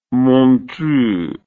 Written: montreux

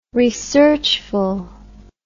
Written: researchful

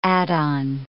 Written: add-on

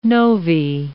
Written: novae

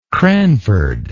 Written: cranford

